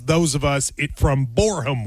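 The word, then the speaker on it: Boreham: It's Borum, not Borham.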